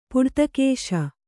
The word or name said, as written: ಪುಡ್ತಕೇಶ